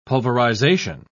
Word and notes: pulverization: British English

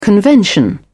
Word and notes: convention: British English

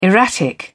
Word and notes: erratic: British English

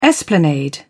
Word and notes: esplanade: British English

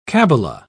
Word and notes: cabala: British English

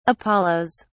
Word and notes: apollos: British English

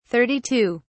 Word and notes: 32: British English

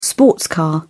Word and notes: sports car: British English

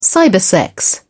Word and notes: cybersex: British English

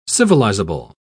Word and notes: civilizable: British English

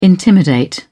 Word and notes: intimidate: British English

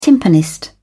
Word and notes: timpanist: British English

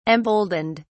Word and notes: emboldened: British English

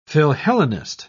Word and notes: philhellenist: British English

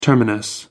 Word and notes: terminus: American English

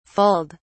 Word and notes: fulled: British English

